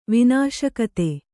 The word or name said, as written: ವಿನಾಶಕತೆ